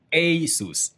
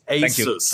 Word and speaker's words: Asus: What is the correct pronunciation? – Asus. – Asus.